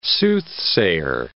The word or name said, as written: soothsayer